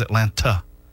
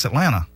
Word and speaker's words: Atlanta: And I'm like, dude, it's Atlanta. – He emphasizes. – Is there another T in there? – I mean, it's like the O in Packlet is silent. The second T in Atlanta is silent.